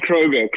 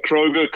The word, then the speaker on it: Kroger: Yeah, so Robbie Kroger, Kroger, correct. It's like the supermarket, and I'll tell you a funny story about that.